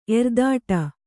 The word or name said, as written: ಎರ್ದಾಟ